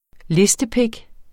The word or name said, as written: listepik